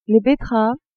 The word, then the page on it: betteraves: female